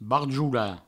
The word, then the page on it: barjolar: barjoula; Provençal